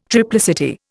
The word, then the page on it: triplicity: Indian